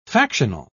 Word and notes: factional: British English

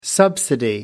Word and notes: subsidy: American English

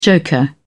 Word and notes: joker: British English

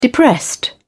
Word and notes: depressed: British English

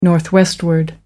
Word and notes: northwestward: American English

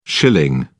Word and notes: schilling: British English

